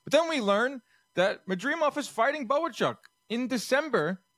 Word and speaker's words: Madrimov: Lampley was pretty pissed when I kept saying it wrong. – It's Madrimov. Okay.